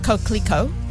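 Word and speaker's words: Coquelicot: It is pronounced Coquelico.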